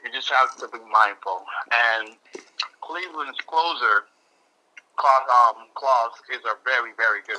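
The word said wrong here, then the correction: Clase: Class A, Emmanuel Class A. – Class A. I said it wrong.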